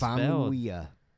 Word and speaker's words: Famuyiwa: You only get one Famuyiwa.